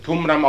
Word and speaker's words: Qumran: Die Gegend heißt jetzt Qumran, wird Qumran ausgesprochen, obwohl mit Kwe geschrieben, es ist ein arabisches Wort.